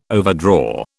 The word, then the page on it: overdraw: British English